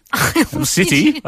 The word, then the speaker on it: Owl City: And, well, we'll see if we get a caller or whether we're playing a game of song lyrics after Owl Shitty. – Owl City. – I was reading the Korean pronunciation there.